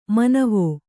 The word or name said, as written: ಮನವೆ